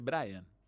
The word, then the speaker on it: Brian: Pero Brian se escribe Brian, se dice Brian. es distinto.